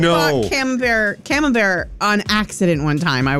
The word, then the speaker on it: Camembert: C-A-M-E-M-B-E-R-T.